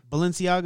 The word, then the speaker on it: Balenciaga: Balenciaga, that's how you say it.